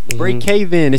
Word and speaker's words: Braek Haven: Brie Caven is spelled B-R-A-E-K H-A-V-E-N.